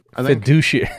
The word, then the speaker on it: fiduciary: Fiduciary? – Fiduciary.